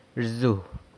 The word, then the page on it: rzu: flies